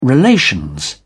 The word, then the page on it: relations: British English